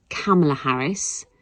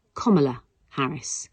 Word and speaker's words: Kamala Harris: I tell you what it's not. It is not Kamala Harris. It is not Kamala Harris. It is Kamala Harris. According to her own memoir in 2019, Kamala Harris says, it's like the punctuation mark, comma, Kamala.